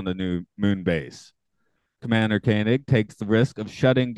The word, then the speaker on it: Koenig: It's Koenig.